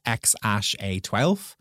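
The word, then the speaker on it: X Æ A-12: So it's XIEA12. So that's actually pronounced X-A12.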